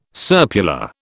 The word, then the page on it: serpula: British English